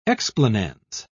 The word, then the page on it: explanans: British English